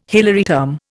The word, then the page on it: Hilary term: Indian